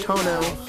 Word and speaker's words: Toño: The tilde is silent. You say it aloud, so it's just Tono.